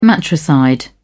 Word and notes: matricide: British English

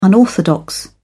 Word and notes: unorthodox: British English

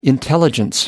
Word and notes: intelligence: American English

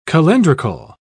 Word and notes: calendrical: British English

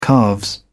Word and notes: calves: British English

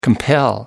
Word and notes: compel: American English

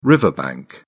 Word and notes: riverbank: British English

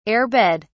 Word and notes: air-bed: British English

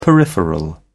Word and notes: peripheral: British English

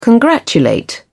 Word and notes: congratulate: British English

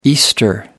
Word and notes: easter: American English

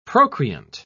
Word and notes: procreant: British English

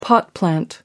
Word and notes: pot plant: American English

